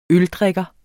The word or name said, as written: øldrikker